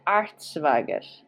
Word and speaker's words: Artschwager: Richard Arzwager. – Okay, could you spell that for me? – Richard. Richard Arzwager. A-R-T. S-C-H-W-A-G-E-R.